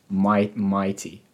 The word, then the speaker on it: MYT: Well, how do you say MYT? How do you say that? Mighty. – Yeah, mighty. M-Y, my, and then the T.